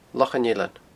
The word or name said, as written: Lochan Eilein